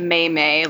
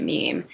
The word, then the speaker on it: meme: I pronounced it May May, like a lot of people do. – um, and then I don't know how I learned. So I probably said it out loud, and someone was like, um, "No, it's it's meme. You pronounce it meme."